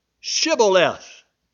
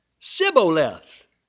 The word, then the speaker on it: Shibboleth: If he say nay, then they say unto him," or then said they unto him, "Say now Shibboleth." And he said Sibboleth, for he could not frame to pronounce it right.